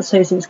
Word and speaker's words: Sozin: And here, Aang goes into the spirit world and kind of talks to Avatar Roku and learns about Sozin's comet, yes, that's how you say it.